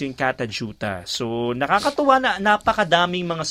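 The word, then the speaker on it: Kata Tjuta: Katajuta yun pa na-pronounce siya. Pero pag in-spell siya, K-A-T-A, isang word na yun, tapos another word, T-J-U-T-A.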